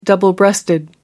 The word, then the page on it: double-breasted: American English